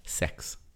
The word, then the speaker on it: CEX: CEX Limited, trading as CEX, pronounced sex.